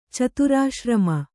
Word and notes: ಚತುರಾಶ್ರಮ: caturāśrama

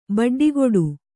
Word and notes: ಬಡ್ಡಿಗುಡು: baḍḍiguḍu

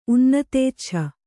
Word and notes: ಉನ್ನತೇಚ್ಛ: kannada

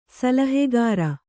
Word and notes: ಸಲಹೆಗಾರ: Kannada

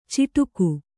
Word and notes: ಚಿಟುಕು: ciṭuku; kannada

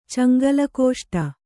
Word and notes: ಚಂಗಲಕೋಷ್ಟ: caŋgala kōṣṭa

